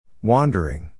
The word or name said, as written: wandering